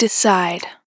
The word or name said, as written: Decide